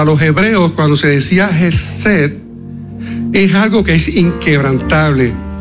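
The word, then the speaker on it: Hesed (חסד): En el idioma original, en hebreo, existe una palabra que se pronuncia Hesed.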